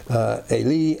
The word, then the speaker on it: Eli: Actually, the correct Hebrew pronunciation is Eli, Eli. The E is pronounced as A, and the I is pronounced as E.